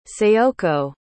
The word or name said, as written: sayoko